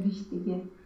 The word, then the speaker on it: wichtige: Păi, nu pot să-l pronunț pe wichtige. – Wichtig, wichtig. – Wichtige. – Wichtige.